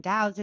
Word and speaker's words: DAOs: Yeah, DAOs.